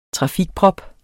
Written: trafikprop

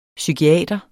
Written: psykiater

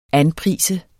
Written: anprise

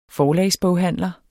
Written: forlagsboghandler

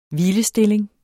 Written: hvilestilling

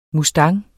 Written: mustang

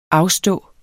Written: AFSTOD

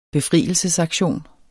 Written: befrielsesaktion

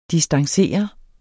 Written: distancere

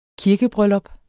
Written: kirkebryllup

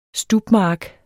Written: STUBMARK

